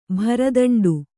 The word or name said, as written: ಭರದಂಡು